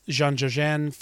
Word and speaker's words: Jean Dujardin: Jean Dujardin for The Artist. I think I got that right. – Yeah, you did.